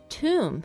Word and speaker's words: tomb: 对，因为第一个词 tomb，嗯，这个坟墓。 – 嗯，T-O-M-B。 – Yeah, so the B there, it's a silent, so it's not tomb, tomb.